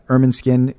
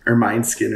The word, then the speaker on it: Ermineskine: You're a member of the Ermineskine Cree Nation. Am I saying that correctly? I always want to make sure. – Yeah, you are actually. Right. That's most people say Ermineskine or something like that. Sure, but yeah, you got it right.